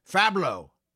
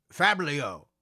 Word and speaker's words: Fablio: The first thing the detectives found was that we had been mistaken in the spelling and pronunciation of the name of this reprobate. We have been referring to him as Fablo. Well, in fact, his name is not Fablo. it's Fablio.